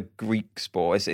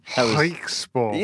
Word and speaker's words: Griekspoor: It's not a Griekspoor. It's a slightly different sound. – Griekspoor.